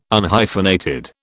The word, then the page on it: Unhyphenated: British English